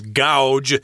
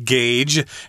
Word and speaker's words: gauge: my wife always mispronounces this word. She says gouge because AU. Isn't that ow? – But uh, this is yeah. a weird word. We pronounce yeah. it gauge as a verb and as a noun.